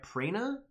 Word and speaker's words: Prana: Prana. It's got the straight line over top, making it a long A.